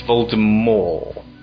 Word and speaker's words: Voldemort: It's odd that at no point in the filming would she sort of step in and go, hang on, it's Voldemort. You're saying it wrong. All of you are.